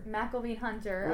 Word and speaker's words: McElveen-Hunter: McElveen-Hunter. – McElveen-Hunter, mm-hmm.